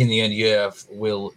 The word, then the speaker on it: Jerv: And Jerv, as is the correct pronunciation, I think, isn't it? – Yeah.